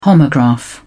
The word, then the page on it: homograph: British English